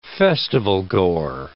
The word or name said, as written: festivalgoer